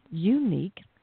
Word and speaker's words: Younique: But there's a company called Unique, and it's spelled Y-O-U-N-I-Q-U-E, Unique.